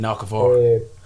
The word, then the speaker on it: Okafor: Bulldogs are going Dufty, I keep on pronouncing this ball for his name wrong, Okador. Is it on the wing? – Jaden Okafor.